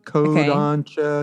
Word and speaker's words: Kodansha: So there is an anime company called, I'm going to mispronounce it, Kodansha. Kodansha, okay. K-O-D-A-N-S-H-A.